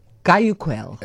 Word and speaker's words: Guayaquil: It's in the town of Guayaquil, which I did Google how to pronounce so that I could come on here and do it correctly. Guayaquil.